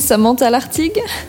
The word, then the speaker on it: Samantha Lartigue: Bonjour, je suis Samantha Lartigue. But I like more the English accent on my name.